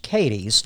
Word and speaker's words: Cadiz: I'm a Kentucky native, born in a place called Cadiz, named for the town in Spain, Cadiz, but it's pronounced Cadiz.